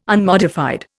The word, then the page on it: unmodified: Indian